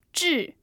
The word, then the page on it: zhi: 4